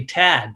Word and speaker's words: Thad: You know, my name's actually Tad, but it's spelled T-H-A-D.